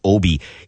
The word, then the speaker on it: Aabye: Now, he wrote this book about Dag Obi. And he's Norwegian. His first name is spelled D-A-G, and his last name is A-A-B-Y-E. It's pronounced Obi.